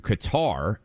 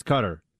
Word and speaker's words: Qatar: I mean, for the Arabic speakers out there, it's Qatar, but we're Americans, so we call things whatever we want.